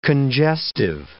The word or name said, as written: congestive